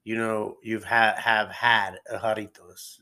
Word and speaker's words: Jarritos: Jarritos. Am I saying that right? Jarritos? – The drink. Jarritos.